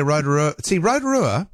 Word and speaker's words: Rotorua: Here's the thing where Rotorua, and I know I'm pronouncing it like, you know, in a European way, but I was born there and old habits die hard.